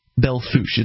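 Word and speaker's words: Belle Fourche: So we have KBFS AM 1450 in, I believe it's pronounced Belfouche. Belfouche.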